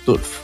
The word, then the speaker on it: Turf: Turf o Tarf. Yo les decía Tarf, claro, porque la pronunciación de esa palabra en inglés pensando que era anglosajona, pero no, es Turf.